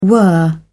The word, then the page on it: whirr: British English